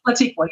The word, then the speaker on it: Latiqua: Latiqua, yes, ma'am.